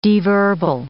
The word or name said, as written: deverbal